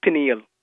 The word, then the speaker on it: pineal: The correct pronunciation for the thing that I was talking about last week is pineal. Pineal, the pineal gland.